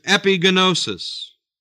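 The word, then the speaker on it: epignosis: The word acknowledgement is epigenosis. E-P-I-G-N, long O-S-I-S.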